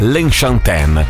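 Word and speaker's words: Lenchantin: Allora, teoricamente la pronuncia del nome di questa artista dovrebbe essere L'Enchantin, perché è un nome francese, anche se lei è argentina, infatti si chiama Paz di nome, Paz L'Enchantin, una grandissima, ottima musicista, anche una bellissima donna che ha 51 anni ma se li porta molto molto bene.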